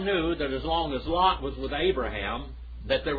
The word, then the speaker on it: Lot: Remember when Abraham and Lot, and by the way, you say Abraham, that's how you say his name in Hebrew. And then Lot is Lot, like Laos, Lot.